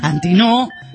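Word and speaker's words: Antinoo: No sé por qué digo Antinú si no es gringo. Antinó. No, pero sí. Se escribe con doble O.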